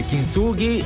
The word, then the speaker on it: Kintsugi: scoperto, la pronuncia è corretta, è Kintsugi, ed è quell'arte,